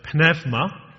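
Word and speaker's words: pneuma (πνεύμα): Now, when a Greek speaker in Luke's day heard the word pneuma, and I'm using the modern Greek pronunciation because that's what our friends across the meadows in St. Andrew's, the first called church, the Greek Orthodox Church over there, that's the way they say this word, so it makes sense to say it the way they say it, because there are people who are saying it today.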